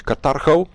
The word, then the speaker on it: Carthago: En dat lijkt het via Carthage. Zeg ik dat goed? Carthago, ja.